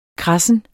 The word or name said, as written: kradsen